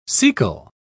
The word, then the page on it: caecal: British English